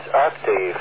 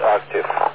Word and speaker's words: octave: Dick, it is octave, not octave. – How about that? A man with a Polish accent is correcting my pronunciation, and he's right. Okay, octave.